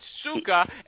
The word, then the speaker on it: Scuka: S-C-U-K-A. – S-E-U-K-A, and it's Suka, and you say if a sales rep calls you, you get pissed off if they don't pronounce your name properly.